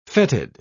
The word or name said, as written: fetid